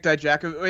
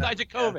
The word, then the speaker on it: Dijakovic: Dominik Dijakovic. It was Dijakovic. – Dijakovic.